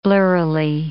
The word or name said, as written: blurrily